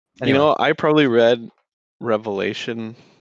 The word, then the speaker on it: Revelation: Right away, you know Joel means business, because one of the most common mispronunciations is revelations. And It drives me crazy. Absolutely drives me crazy when people say that.